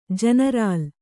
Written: ಜನರಲ್ಲು

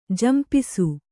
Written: ಝಂಪಿಸು

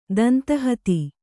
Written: ದಂತಹತಿ

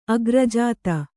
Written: ಅಗ್ರಜಾತ

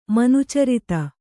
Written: ಮನುಚರಿತ

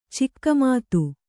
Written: ಚಿಕ್ಕಮಾತು

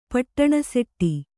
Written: ಪಟ್ಟಣಸೆಟ್ಟಿ

